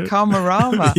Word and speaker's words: Karmarama: And then Karmarama? – Yeah, Karmarama.